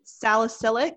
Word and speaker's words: salicylic: Salicylic, did I say it right? – Yeah.